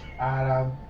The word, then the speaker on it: Arabidopsis: Arabidopsis. There's a P in there. Arabidopsis. Yeah, I said it right.